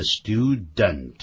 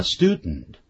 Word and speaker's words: student: 所以我们讲的学生叫 student，它不念成呢所谓的 student，叫 student，用鼻音出来。